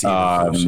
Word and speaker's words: Eberflus: Matt Eberflus, is that how you say his name? I'm not quite sure. – Yes, it is. It's Eberflus, yes.